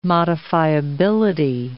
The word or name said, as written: modifiability